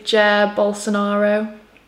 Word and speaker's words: Jair Bolsonaro: I say Jair Bolsonaro.